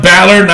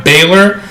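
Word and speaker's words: Bálor: Apparently, it was supposed to be Brock Lesnar versus Finn Balor, but, and it's pronounced Balor, not Baylor, but Vince is still unsure if he wants to go ahead with it.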